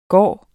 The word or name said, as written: gak